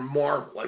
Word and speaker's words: Marvel: Is it Marvell or Marvel? I wanted to be sure of that. – It's actually Marvel, like the comic book.